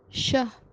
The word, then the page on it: shah: Kashmiri